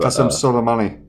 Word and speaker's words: Qasem Soleimani: Qasem Soleimani? I want to say that's the correct pronunciation from what I understand about Qasem Soleimani. – Yeah, that sounds about right.